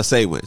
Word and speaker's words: Samhain: I should have kept the, because I keep butchering the name, but Samhain. – Oh, okay. – Sorry, y'all. Samhain.